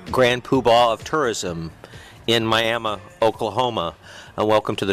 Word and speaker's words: Miami: So I picked Miami, Oklahoma, which coincidentally it's spelled like Miami. Well, when those Native Americans were in the Carolinas, and when they were marched off of their land, a few of them ended up in Florida, pronounced Miami, Miami, and a lot of them ended up in Oklahoma, where Miami became Miami.